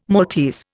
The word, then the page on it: Maltese: Indian